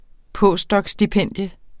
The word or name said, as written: postdocstipendium